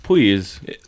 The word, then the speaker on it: Puig: After Loflason caught the ball with his face from Ricky Puig. – Is it Puig or Puig? Because it's Puig. – The Catalan pronunciation is Puig. Oh, Puig. Okay.